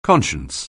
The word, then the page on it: conscience: anglais